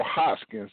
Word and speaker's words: Hoskins: Here with artist Jamal Hoskins.